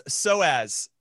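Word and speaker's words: psoas: It's pronounced Soaz.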